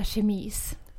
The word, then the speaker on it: chemise: Jag kör en skjorta från La Chemie. Och ett par jeans från... – Uttalar man det så? – Nej, tror jag. Mm. – La Chemise. – Nej. – Skjorta på franska heter chemise.